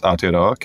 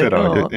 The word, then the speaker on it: Aotearoa: Off air, I was just explaining how to pronounce Aotearoa. Can we get another one? – Aotearoa, New Zealand. Close. I love it. Is it right? – It sounds a little bit off.